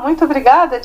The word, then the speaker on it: muito obrigada: Si no lo digo mal, es muito obrigada. – Ah, muito obrigada, de nada, um prazer, grande prazer, Cristina.